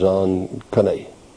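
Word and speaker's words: Zonkane (זקן): The word is Zalkan. Z-A-Q-E-N, pronounced like Zal, Z-A-W, then K-A-N-E, Zonkane. Actually, because you got the emphasis on the kan.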